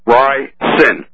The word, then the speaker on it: ricin: Ricin. R-I-C-I-N. You've been inserting an A after the I. Don't do that. – Now, ricin. – Yes, sir. – You're right. That is how you're supposed to pronounce it.